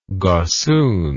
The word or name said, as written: gossoon